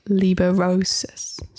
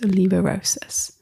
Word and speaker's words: liberosis: It's pronounced liberosis. So maybe I said it wrong. So liberosis, the desire to care less about things.